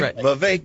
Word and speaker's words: Vivek: Vivek. Just remember one thing. Vivek rhymes with cake.